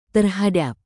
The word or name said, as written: terhadap